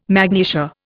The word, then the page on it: magnesia: Indian